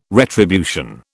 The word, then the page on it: retribution: British English